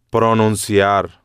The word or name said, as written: pronunciar